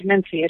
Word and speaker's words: mensae: They're called mensae. It's just a Latin word for, based on the Latin word for table.